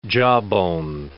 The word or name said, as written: jawbone